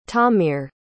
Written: tamir

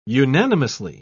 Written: unanimously

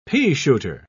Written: peashooter

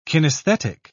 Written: kinesthetic

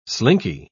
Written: slinky

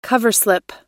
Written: coverslip

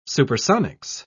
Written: supersonics

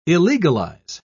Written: illegalize

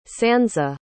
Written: sansa

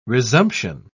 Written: resumption

